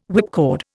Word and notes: whipcord: Indian

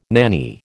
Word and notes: nanny: American English

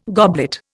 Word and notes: goblet: Indian

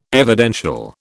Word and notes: evidential: British English

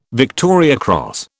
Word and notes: Victoria Cross: British English

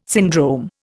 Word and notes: syndrome: Indian

